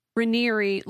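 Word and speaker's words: Raniere: She says it's pronounced Ranieri, like canary, but Ranieri.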